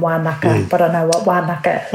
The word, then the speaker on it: Wānaka: My understanding is that, yes, it's Wānaka, which is the Ngaitahu pronunciation for Wānanga. In Ngaitahu, a lot of our NGs are pronounced as Ks.